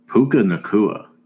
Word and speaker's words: Puka Nacua: But Puka Nacua, I think that's how you say it, right? – Yeah, that sounds right.